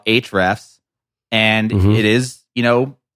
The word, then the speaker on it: Ahrefs: Ahrefs. So it's spelled Ahrefs.